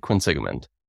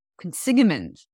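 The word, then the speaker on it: Quinsigamond: Yeah, it's Lake Quinsigman. – Quinsigman.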